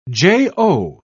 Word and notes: jo: British English

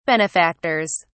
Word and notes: benefactors: British English

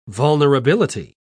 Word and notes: vulnerability: British English

